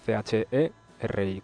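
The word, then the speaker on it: Chérie: El título, que no lo he dicho, es Cherie. No sé si lo he pronunciado bien, pero vamos, sería Cherry, con acento, con tilde en la E, ¿vale?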